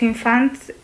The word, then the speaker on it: infants: Nos estamos focalizando en los infants, ¿lo he dicho bien? – Molt bé, sí.